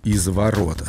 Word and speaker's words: Изворот: «Изворот» в одно слово, то есть не то, что кто-то вышел из ворот, а «изворот» от слова «изворачиваться». Так что, может быть, даже это надо произносить не «изворот», а «изворот».